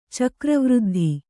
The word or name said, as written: ಚಕ್ರವೃದ್ಧಿ